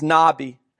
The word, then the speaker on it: nabi: Now, the word for prophet in Hebrew is nabi. You would spell it in English N-A-B-I.